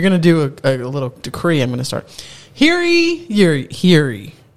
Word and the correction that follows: hear ye: Here he. It's ye. – Here ye. – Like think, hear think ye.